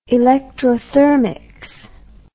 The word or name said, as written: electrothermics